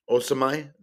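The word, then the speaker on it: Osimhen: Plays on the same team as Victor Osimai. Osamai, that's how you actually pronounce it. It's Osamai. Uh, you don't pronounce the N.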